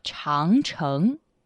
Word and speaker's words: 长城: It's pronounced 长城. – 长城. – Mm-hmm. 长 means long, and 城 means wall. Together, it's 长城.